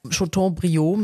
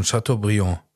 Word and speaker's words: Chateaubriand: Chateaubriand mit Soße, guck mal da. – Du hast es jetzt schon falsch ausgesprochen, Chateaubriand.